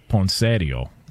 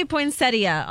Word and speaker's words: poinsettia: Well, the in-laws would call it poinsettio. – Oh gosh. – Okay. What up? You know what I'm saying? – Oh, God. – Can we get the proper pronunciation on this? – It does say poinsettia online.